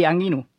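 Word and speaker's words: angirũ: ¿Y cómo se pronuncia bien? – Y Anguirú. – Ah, sí, Anguirú.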